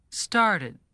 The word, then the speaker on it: started: Note, Sam, that the past tense of start is started. You add an extra syllable. Start, started.